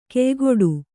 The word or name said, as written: ಕೆಯ್ಗೊಡು